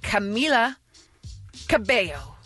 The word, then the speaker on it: Camila Cabello: October 12th, David Harbour with Camila Cabello.